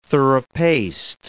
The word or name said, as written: thorough-paced